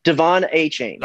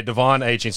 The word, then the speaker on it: Achane: And then how do you pronounce Devin's last name again? – Devon Achane. – Okay, Devon Achane.